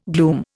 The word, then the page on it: glume: Indian